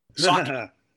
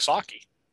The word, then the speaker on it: sake: Sake. I wouldn't have a problem using or serving sake. – And there you go. Yeah. – Or sake, I guess it's actually how it's pronounced.